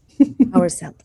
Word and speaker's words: Horselt: Horselt. So hour like time. – Horselt. – Belt. – Okay. – Like belt. – So yes. I have got Horselt.